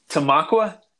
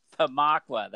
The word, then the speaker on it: Tamaqua: It's Tamaqua. – Tamaqua. That is correct.